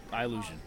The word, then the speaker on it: Eyellusion: Yeah, Eyellusion.